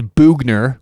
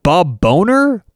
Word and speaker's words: Boughner: And their coach, Bob Bugner, which, by the way, I had to look this up, Bob Bugner. It is Bugner, which is a pretty funny last name as it is, but it's spelled B-O-U-G-H-N-E-R. is it Bob Bonair? Bob Boner? It's Bob Bugner.